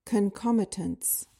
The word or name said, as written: concomitance